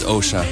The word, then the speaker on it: Osha: Is Osha, apparently. – Oh, really? I never knew that. Osha? – Yeah, Osha. Thanks. – Osha. How do you spell that? – O-S-H-A. – Oh, Osha. Okay.